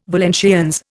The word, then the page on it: Valenciennes: Indian